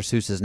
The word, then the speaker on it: Seuss: It rhymes with voice, so it's Dr. Seuss.